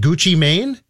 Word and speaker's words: Gucci Mane: So now the Strib found out that rapper is it Gucci Mane Yep, there you go. Nailed it.